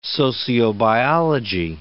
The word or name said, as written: sociobiology